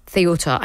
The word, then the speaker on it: Ceuta: Ceuta. I've seen many different pronunciations. I'm going to go with what Miriam said. I was told earlier it was Ceuta. I'm now going to go with Ceuta.